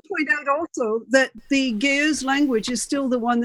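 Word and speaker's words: Ge'ez: Beady-eared listeners will hear that the language is pronounced Geuz, is that right? – Geuz, yeah. – Geuz, okay. – There's an apostrophe in between. Yeah, I said Geuz.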